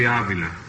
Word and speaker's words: Avila: Bobby Avila. Do I pronounce that right? – Yeah, this is right from the system of my name, Frankie. – Let me have that again, please. – Bobby Avila.